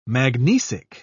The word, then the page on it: magnesic: British English